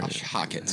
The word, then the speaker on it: Josh Hockett: Josh Hockett.